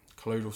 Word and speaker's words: colloidal: Change it to colloidal, colloid, colloidal silver. That's how you pronounce it.